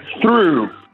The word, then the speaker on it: through: Allora, perché è difficile da pronunciare? Perché noi italiani, ok, che parliamo una lingua fonemica, ovvero dove i suoni corrispondono alle lettere, ci vediamo tutto questo grande ammasso di lettere, quindi T-H-R-O-U-G-H, e entriamo nel pallone. Diciamo, come si pronuncia questo G-H? Buh.